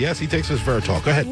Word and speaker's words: resveratrol: This person has been studying the potential benefit of resveratrol. Did I say that right? – Resveratrol. – Resveratrol.